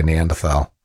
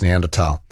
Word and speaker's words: Neanderthal: I hate to correct Kelvin, but as someone who has a postgraduate degree in German, I have to tell him that the H in Neanderthal is in fact silent and not pronounced. So I think Kelvin's saying that in English you can sort of say Neanderthal. Can you? I'm not sure what the convention is actually, but yeah. If you're being strict to the German, it's Neanderthal.